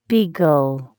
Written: beagle

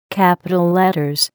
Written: capital letters

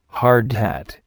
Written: hard hat

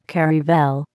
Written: caravel

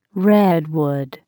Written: redwood

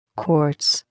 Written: quartz

